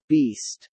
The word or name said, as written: beast